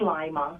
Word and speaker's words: Lima: Am I saying your name right? – Yes, it's Lima. – Lima. It really is like lima bean.